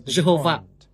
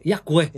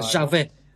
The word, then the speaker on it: Yahweh: Từ đó mà ra từ Jehovah, nhưng à, đó là một sự hiểu lầm, bởi vì G được phát âm giống như Y và V được phát âm giống như W. Vì vậy trong tiếng Anh, phát âm của nó phải là IHWH, có nghĩa là à, Yahweh hay là Jahwe